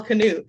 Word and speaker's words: Knut: K-N-U-T. And it's pronounced Knut. So, of course, I say Val Knut. And everybody I know around here says Val Knut or Val Knut. And I'm like, no, no, it's Val Knut, Val Knut.